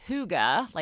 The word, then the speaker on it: hygge: So, pronounced hygge, like H-O-O-G-A, hygge, this